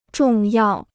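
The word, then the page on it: 重视: zhòng shì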